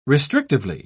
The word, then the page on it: restrictively: British English